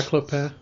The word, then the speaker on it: Clopin: Yeah, Clopin.